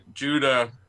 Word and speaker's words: Guida: It's spelled G-U-I-D-A, which I always pronounce Judah. I mean, I didn't pronounce it. I pronounced it Guida, but there's the band, the band with the same spelling, and they pronounce it Judah. So I don't know. – There's an MMA fighter named Clay Guida, and it's G-U-D-I-A.